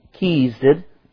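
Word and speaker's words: Chesed: U-E-L. Kemuel, the father of Aram. And Kezed, that's pronounced K-E/S-E-D. I wanted to say cheese, but it wasn't correct. So, and then there's Hazo.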